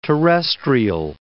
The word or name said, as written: terrestrial